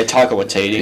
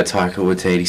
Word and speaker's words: Taika Waititi: such as Taika Waititi and all that. Yeah, that's how you say his name. Yeah, Taika Waititi. Yeah, Taika Waititi, something like that.